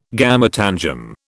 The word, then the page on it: gametangium: British English